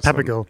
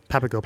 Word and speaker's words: Papago: Papago. So Papago.